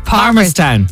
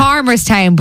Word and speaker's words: Palmerstown: Palmerstown, yeah. – Palmerstown, but the L is silent. – Palmerstown. – You just say Palm Sunday.